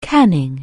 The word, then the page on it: canning: anglais